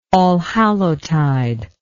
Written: allhallowtide

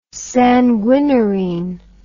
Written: sanguinarine

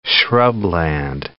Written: shrubland

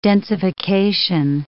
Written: densification

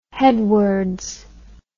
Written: headwards

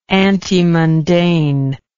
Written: antemundane

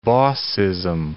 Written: bossism

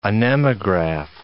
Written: anemograph

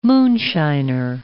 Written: moonshiner

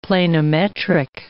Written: planimetric

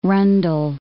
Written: rundle

